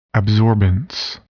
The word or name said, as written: absorbance